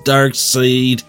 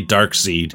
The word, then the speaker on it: Darkseid: No, but you will in the future when I come to this uni- universe again to defeat Darkseid. Dark Side, however it's pronounced, replied Link with knowledge. First time I see it, I always say Dark Seed. Then I remember it's Darkseid because fuck Jack Kirby.